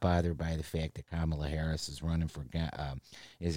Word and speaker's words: Kamala: But it's Kamala. That's how you say it. You say it right.